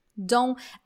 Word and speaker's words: don't: Don't. Oh, uh, a pronúncia de don também é legal. Ó. Você não precisa pronunciar o T do don't.